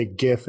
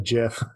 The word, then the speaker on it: GIF: And I got to say, Jake Miller would be very happy to hear you say GIF instead of JIF. – I refuse to say JIF. Yeah. And I think it's a friend of mine. So, I can't. I have to go with the hard G sound.